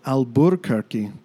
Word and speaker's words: Alburquerque: The loss of that first R from Alburquerque to Albuquerque, a side effect of Anglo-American tongues fumbling over the original Spanish pronunciation.